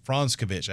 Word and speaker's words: Franskovich: I said it right. That's Franskovich. I had to say it at the podium last week. So I asked the principal Ferris, two R's, at Clover High School. I said, hey, how do you pronounce his name? And she said, Franskovich. I was like, there you go.